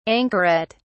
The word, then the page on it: anchoret: British English